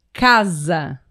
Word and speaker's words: casa: A primeira palavra é casa. Perfeito. – Casa. – Apesar de se escrever com S, né? A casa tem o som do Z. Casa.